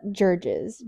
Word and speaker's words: Gerges: My name is Lauren Gerges. – How do you pronounce it? – Gerges. – G-E-R-G-E-S. I always say Gerges.